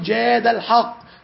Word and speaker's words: جاد الحق: لو تنطق الاسم صح هتقول جاد الحق، جاد فعل ماضي والحق فاعل تمام، أو جاد الله تمام.